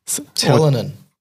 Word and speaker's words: ptyalin: Talanin. – So how do you pronounce P-T-Y-A-L-I-N? – P-T-Y, so like pterodactyl, tear. – Talanin. – Talanin. – So this... – Talon. It's just talon. – Just talon. – Yeah. – Okay. – P-T-Y-A-L-I-N.